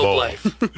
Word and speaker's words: Globe Life: We have Mark Creamer, and he is with Family Heritage Division Global Life. Did I say that right? – Yeah, Global Life Family Heritage Division is technically how they say it. – Sorry, dyslexic. – Globe, not global. – Globe Life.